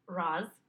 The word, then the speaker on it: Ra's al Ghul: They have a Ra's al Ghul who everyone has said, I'm sorry, Ra's is the correct pronunciation, but, they have a Ra's al Ghul, who is just everyone has been saying how spectacular he is.